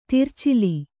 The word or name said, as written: ತರಚಲು